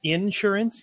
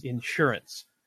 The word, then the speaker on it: insurance: And for those of you listening from the North, when he says insurance, he means insurance. So he's putting the emphasis on the wrong syllable.